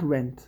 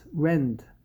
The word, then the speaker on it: rend: Not rent. Rend. Should be rend.